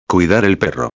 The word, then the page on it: cuidar el perro: espanhol